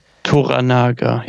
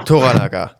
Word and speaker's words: Toranaga: Toranaga, ja. – Toranaga, genau. So ist es ausgesprochen, richtig.